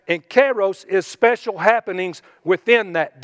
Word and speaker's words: kairos: Now, there's another part, another word that represents a form of time called kairos. And if you want to spell that, it's kind of like kairos syrup, only it's K-A-I-R-O-S.